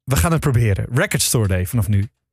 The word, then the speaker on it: Record Store Day: De klemtoon ligt bij jullie op Record Store Day. Maar het zou in my opinion Record Store Day moeten zijn.